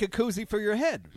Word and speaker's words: koozie: What is it? Koofy? What are you? What, am I saying it wrong? The little thing that you put on it to keep it cold. – Oh, koozie.